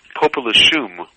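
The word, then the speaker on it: Popelouchum: The pronunciation that I use is Popoluchum.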